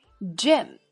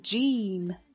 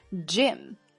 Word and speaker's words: gym: gym 要注意短元音 a，短促有力。如果读成长元音 e，就变成 gym，不够好听啊。 gym gym。